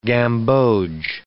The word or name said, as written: gamboge